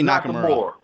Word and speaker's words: Nakamura: Just say Nakamura. Nakamura.